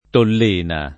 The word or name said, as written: Tollena